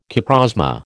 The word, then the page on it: coprosma: American English